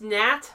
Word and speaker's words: gnat: By the way, it's pronounced Nat.